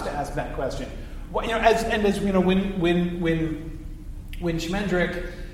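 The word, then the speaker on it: Schmendrick: Shmendrick is a pretty, and that is how it is pronounced.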